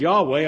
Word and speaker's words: Yahweh: Y-H-W-H. I don't really know how to pronounce it. The Masoretic scribe said to pronounce it Yahweh.